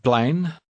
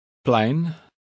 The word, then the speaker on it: plein: Het plein. Plein. Plein. Het. Het plein. Plein. – The middle sound in the word for square is one we don't have in English.